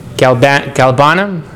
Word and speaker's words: galbanum: Galbanum.